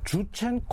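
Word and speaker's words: Jurchen: 그러니까 지금식으로 말하면 여진이 영어 발음으로 주첸이거든요. 그러니까 주첸 코리안이 된 거예요.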